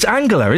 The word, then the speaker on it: Angela: Is it Angela or Angela? It's Angela, isn't it? It's a hard G.